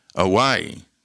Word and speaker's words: Awai: Nope, Damien Awai. That's how you say it. Damien Awai. See, in Hawaii, the W becomes the V, but not in all names. And his name is A-W-A-I.